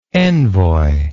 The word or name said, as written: envoyship